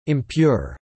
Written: Impure

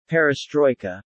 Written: perestroika

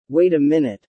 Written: Wait a minute

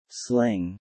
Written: Sling

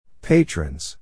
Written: patrons

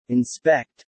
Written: inspect

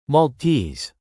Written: Maltese

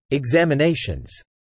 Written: examinations

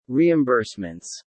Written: reimbursements

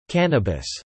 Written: cannabis

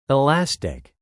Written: elastic

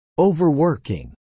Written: overworking

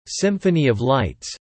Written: Symphony of Lights

